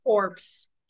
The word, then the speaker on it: Corps: Also, isn't it Marine Corps? I think the P is silent. – I'm dead. I'm a dumbass. – Corps.